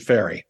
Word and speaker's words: Ferre: You're promoting a book which you've wrote with Ferrer, F-E-R-R-E. – F-E-R-R-E, but he pronounces it Ferry, just Ferry. Like the English musician.